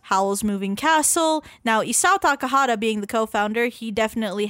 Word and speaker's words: Takahata: It's Takahata.